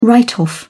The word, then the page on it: write off: British English